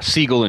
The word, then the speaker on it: Siegel: And the guy that invented it, Jerry, is it Siegel pronounced? – Yeah, Siegel and Schuster.